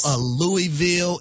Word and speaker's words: Louisville: Y'all aren't saying Louisville, Louisville. It's Louisville, and I'm so happy and so thankful Louisville, that y'all are saying it correctly.